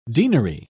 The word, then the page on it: denary: British English